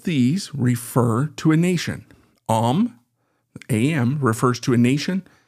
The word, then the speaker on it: Am: We have the word Am, which is spelled A-M. You would read it like Am, and that's what we see here.